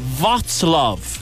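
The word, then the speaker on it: Wrocław: This trip in Poland, it's a place called Wroclaw. That is what it's pronounced. It's spelt Wroclaw.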